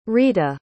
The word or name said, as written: rheta